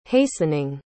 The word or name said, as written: hastening